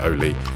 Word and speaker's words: Auli: It's written A-U-L-I, but I'm assured by Norwegian friend Benta that you pronounce it Oli, so if I've got that wrong, blame her.